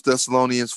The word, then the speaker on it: Thessalonians: So 1 Thessalonians 5:23.